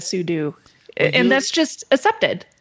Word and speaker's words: sudo: But the co-inventor, Robert Cogshall, says it's meant for super user do. You should say sudo. So in this case, Axon ruled sudo. Sudo is the proper pronunciation. We've just all been saying it wrong.